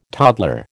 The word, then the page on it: toddler: American English